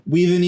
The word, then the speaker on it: Whedon: Whedon, yeah.